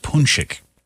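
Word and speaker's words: pączki: Today's the day you need to go and get some delicious punschkes, or as Jocelyn mentioned earlier, I guess the plural is pronounced punschik.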